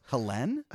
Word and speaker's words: Helene: Helene, yeah. – It's a little annoying, but... – Why is it annoying? – It's clearly Helen. – Yeah, but it's not in French.